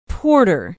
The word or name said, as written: porter